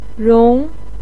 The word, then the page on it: rong2: pinyin